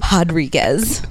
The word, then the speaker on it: Rodriguez: With an R. But a huh. – But it's pronounced Rodriguez.